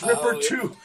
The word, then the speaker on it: RPRII: And Neptune, Arpril. Did I pronounce that right? No. – Ripper, I think that might be Ripper 2. – Oh, it's Ripper 2. – Yeah. Okay. Yeah, RPRII.